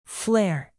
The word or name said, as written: flare